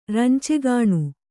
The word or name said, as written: ರಂಚೆಗಾಣು